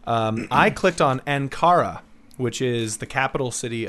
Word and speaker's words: Ankara: I clicked on the listen button and pronounced it Ankara.